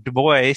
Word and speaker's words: Du Bois: Your book is Subversive Witness, Scripture's Call to Leverage Privilege, Dominique Dubois Gilliard, G-I-L-L-I-A-R-D, right? – Yeah, I go with the U.S. Pronunciation of Du Bois because I'm but that's the right name because all three of my names are French, and so that would have been the right global pronunciation, but I'm named after W.E.B.